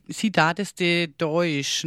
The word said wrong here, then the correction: Cidade de Deus: ne? So spricht man es aus. – Cidade de Deus. – De Deus, genau, so.